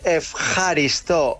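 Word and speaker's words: efjaristo (ευχαριστώ): ¿Cómo se dice gracias? – Esta es la difícil, esta es la difícil. – A ver. – Se dice efjaristo. – Efjaristo. Efjaristo. Bueno, muy bien.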